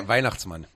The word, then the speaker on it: Weihnachtsmann: Weihnachtsmann. – ¿Cómo se dice? Weihnachtsmann.